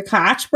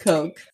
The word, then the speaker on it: Koch: I can't read an article about the Coke brothers without being, like, the Koch brothers. But all I'm right, pretty sure it's pronounced Coke.